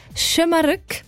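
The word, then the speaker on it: Şımarık: Also man sagt Schömerück.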